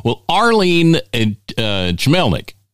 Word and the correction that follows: Chmelik: Chmelik? That's it.